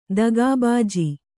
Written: ದಗಾಬಾಜಿ

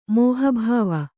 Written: ಮಹಾಬೇವು

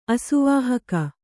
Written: ಅಸುವಾಹಕ

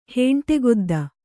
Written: ಹೆಂಟೆಗೊದ್ದ

